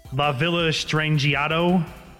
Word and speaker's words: La Villa Strangiato: La Villa Strangiato.